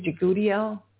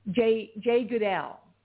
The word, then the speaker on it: Jegudiel: I think it's Jagudiel. J. Goodell. It's spelled J-E-G-U-D-I-E-L.